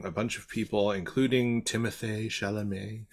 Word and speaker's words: Timothée Chalamet: And I'm sorry, I have to say his name like that because he has an accent aigu on his E, his double E at the end of his name. So Timothée Chalamet.